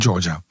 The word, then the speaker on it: Georgia: Georgia, in Georgia. Klopt dat? Zeg ik dat goed? Ja, dat zeg je helemaal goed.